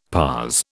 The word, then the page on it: parse: British English